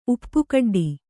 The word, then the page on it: ಉಪ್ಪುಕಡ್ಡಿ: uppa kaḍḍi